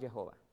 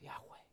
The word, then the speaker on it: Yahweh: We don't actually know the real pronunciation of that name. Yahweh is probably the closest one. Not Jehovah. That's not good. It's Yahweh. Yahweh.